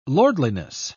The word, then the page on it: lordliness: British English